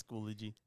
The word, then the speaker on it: Schoology: So I always pronounce it wrong. – Schoology. – Schoology. – Yeah, it's Schoology.